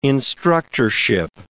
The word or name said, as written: instructorship